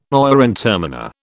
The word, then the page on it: oyer and terminer: British English